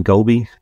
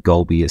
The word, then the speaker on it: Goleby: Yeah, if you just search Brendan Golby, B-R-E-N-D-A-N, and then Golby is spelled G-O-L-E-B-Y.